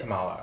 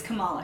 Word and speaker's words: Kamala: Kamala. – It's Kamala Harris.